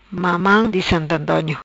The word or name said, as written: Mamam Di Sant Antonio